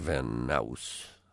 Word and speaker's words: Van Nuys: Petra Van Nuys, rhymes with mouse, and Andy Brown.